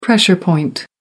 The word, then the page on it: pressure point: American English